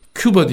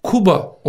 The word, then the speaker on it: Kuba: Küba diye yazıyorlar. Ya şimdi Küba nerede, Kuba nerede? Telaffuzu zor, bizim insanlarımız bunu telaffuz edemiyor derse, telaffuz edebilecekleri bir isim koysun. Yani bir insan çocuğunun ismi telaffuz edilemeyecekse illa da o ismi koymaz. Yanlış telaffuz ediyorlarsa. Doğrusunu yazmak icap ederse Kuba olarak U ile yazmak lazım.